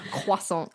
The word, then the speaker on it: croissant: That's how you say it. – Yeah. CR does not produce a Q sound. No, thank you. – Croissant.